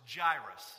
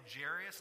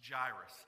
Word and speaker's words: Jairus: Then a man named," and then this is, gets a little hairy because I've mispronounced it a whole lot, but it's Jairus. So if I say Jairus, that's wrong. Okay, correct me. It's Jairus.